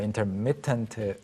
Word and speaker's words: intermittent: 네, 기본적으로 intermittent yeah. – fasting이라고 합니다. 미 부분에 강세가 있는 게 일반적이고.